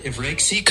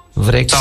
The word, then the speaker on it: βρέξει: Telaffuzda vrexi, yani evet. – Vrexi kısmında V yumuşak, R de hafif titrer. Denemek evet. İstersen birlikte söyleyebiliriz. – Söyleyelim. Hadi sen söyle önce. Vrexi.